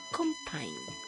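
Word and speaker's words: Compiñe: ¿Cómo se escribe? Compa y una ñ. Y se pronuncia Compine.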